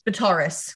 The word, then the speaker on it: Vitarus: Okay, the god is pronounced V-I-T-A-R-U-S. – Vitaris. Let's go with it. – And their town, is called Adkova. A-D-C-O-V-A, Adkova.